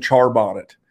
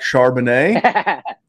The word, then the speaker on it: Charbonnet: Do not call him Charbonnet. It is Zach Charbonnet. And if you call him Charbonnet on a podcast, we will ridicule you. But a guy like Zach Charbonnet, even Zach Evans – a guy that I like a little bit, Tank Bigsby.